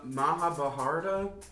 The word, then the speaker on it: Mahabharata: I'm gonna butcher this. The Mahabharata. That was close enough. – That was pretty good.